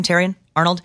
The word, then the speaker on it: Terrion Arnold: Is that how you pronounce his name, Terrion Arnold? – Yes.